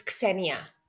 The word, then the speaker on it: Xenia: No, you're pronouncing it quite well. It's Xenia. – Okay, so the K and the S are together.